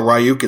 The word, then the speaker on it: Ryuk: So like one good example, there's a really bad strain of ransomware that was hitting a lot of healthcare organizations called, I don't know how you pronounce it. I call it Ryuk. It's R-Y-U-K.